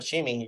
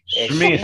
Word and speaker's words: Shmi: Sí, no Shimi, yo no sé dónde sacaste el Shimi. – Shmi, sí, exacto. – mala pronunciación, mala mía.